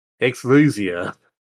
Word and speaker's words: Exusiai: Exlusia. It's spelled E-X-U-S-I-A-I. But pronunciation is like X-Z-U-Z-I-A.